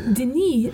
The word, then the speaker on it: Denis: Denis, the French pronunciation of Dennis,